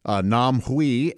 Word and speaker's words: Nam Hui: You would pronounce it like H-W-E-E, Nam, as in Vietnam, although she was Korean. Nam Hui, and I met her because she had a son, a young son who wanted to learn English.